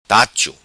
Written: Tacho

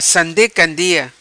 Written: Sande Candia

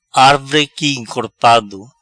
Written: Arvre Qui Encorpado

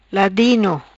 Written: Ladino

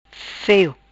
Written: feo

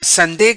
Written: Sande

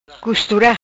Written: Costura

